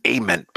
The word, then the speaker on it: Ament: I think it's Ament, by the way. – Hey, Ament. Apologies if I've been mispronouncing that.